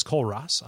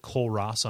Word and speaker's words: Kole Ross: He is at DFuria, D-F-U-R-I-A, on Twitter. My name is Cole Ross. I am your host. I am found at Cole Ross on Twitter, K-O-L-E-R-O-S-S.